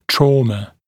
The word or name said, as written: trauma